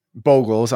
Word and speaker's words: Bogles: Yeah, my number four is Slippery Boggle. And obviously, this extends to all surrounding Bogles. I'm sorry, I said Boggle. I hate when I mispronounce that word.